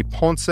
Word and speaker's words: Ponce: I'm at Anthony Ponce on Facebook, and I'm at Anthony Ponce TV on Twitter and Instagram. Last name is spelled P-O-N-C-E.